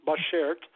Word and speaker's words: Bashert: It is pronounced Bashert, B-A-S-H-E-R-T, Bashert. It's a Yiddish word, and it means soulmate.